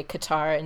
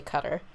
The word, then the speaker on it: Qatar: But it depends on how you say it. Some people say Qatar and some say cutter.